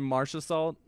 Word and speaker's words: Marchessault: They need to get some writers on it, because the one he did with Jonathan Marchessault from the Marchessault? I think there might be a couple silent letters at the end of that guy's name, which makes sense that they chose him.